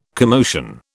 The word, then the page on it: commotion: British English